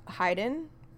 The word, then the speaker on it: Haydn: So it's pronounced Haydn, Brendan, Joseph Haydn.